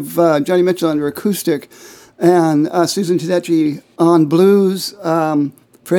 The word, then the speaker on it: Susan Tedeschi: And whichever one of you did, you can help me with the pronunciation. Susan Tedeschi? – Yeah. You've got the pronunciation right, and she is on my list.